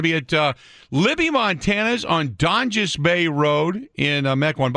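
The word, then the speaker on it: Donges: By the way, it's not Dongus. As Jeff Orlowski thought it was. I'm glad you said Donjus. I didn't know how to pronounce it. I thought it was Dongus.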